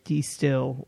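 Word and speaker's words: De Stijl: Yeah, it's pronounced D-Still, which is D-Style.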